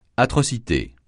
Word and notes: atrocité: francais

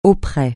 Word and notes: auprès: francais

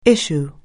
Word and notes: issue: anglais

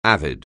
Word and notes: avid: anglais

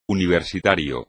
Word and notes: universitario: espagnol

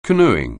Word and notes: canoeing: anglais

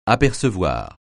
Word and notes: apercevoir: francais

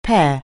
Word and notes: pear: anglais

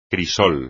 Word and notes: crisol: espagnol

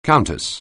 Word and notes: countess: anglais

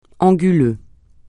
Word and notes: anguleux: francais

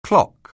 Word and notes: clock: anglais

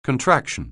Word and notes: contraction: anglais

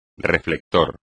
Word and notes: reflector: espagnol

